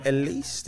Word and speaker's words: Ellyse: I'm joined by Ellyse.